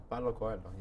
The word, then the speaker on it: Paulo Coelho: I believe it was one of, I'm probably not going to pronounce his name right, but Paulo Coelho? – Oh, Paulo Coelho, yeah.